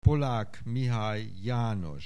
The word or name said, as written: Pollack Mihály János